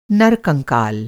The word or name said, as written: नरकंकाल